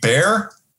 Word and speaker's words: Bexar: And he said, Bexar County. I said, you mean bear? He goes, is that how you say it? I said, yeah.